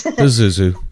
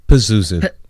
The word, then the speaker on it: Pazuzu: Pazuzu. – Pazuzu? – Pazuzu, with a P.